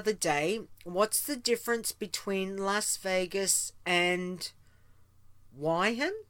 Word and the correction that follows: Wuhan: Is it Wyhan? What happens in Vegas stays in Vegas. Between Las Vegas. I don't get it. Am I missing something? Did I pronounce it wrong? Is where Wuhan.